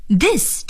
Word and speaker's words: this: 再来看看 this 这个词， T-H-I-S, this, this 是指这个，这。在这里呢请朋友们注意 TH 的发音，在发 TH 这个音的时候呢一定要把舌尖放在上牙齿和下牙齿的中间。